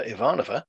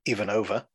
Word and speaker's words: Ivanova: Meanwhile, in the CNC, the perky and energetic Commander Ivanova, pronounced correctly, Ivanova, twice, she's looking into Jakar's claims about the transporter.